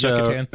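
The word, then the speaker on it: Yucatán: Yucatan Peninsula. – Mm-hmm. – And I don't know if I said that right. – Yeah, Yucatan. – It's Yucatan.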